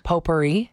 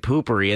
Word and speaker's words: potpourri: Potpourri. – Okay. Because there's poopery as well. – Oh, am I saying it wrong? – Yeah, you were saying poopery, and that's the stuff you put in the bathroom when you spray in the toilet before you take a dump.